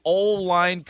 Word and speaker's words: Olin: And Olin, the first text that I see on our fully refreshed screen at 670-11 is Olin interviewing for the O-line coach position? He should be. So, Olin. – His name is actually pronounced O-line Kreutz.